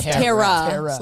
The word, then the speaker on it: Tiera: Is it Tiera or Terror? – No, it's Tiera. Like T-A-R-A.